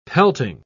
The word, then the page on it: pelting: British English